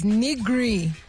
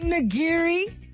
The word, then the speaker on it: nigiri: And then there is another response that's come here that says nigri. Nigri. Is that how it's said? Nigri. N-I-G-R-I. Isn't it nigiri?